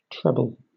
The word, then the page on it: treble: British English